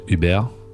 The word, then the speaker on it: Hubert: Of course, Hubert is pronounced Uber in Quebecois French, or in French.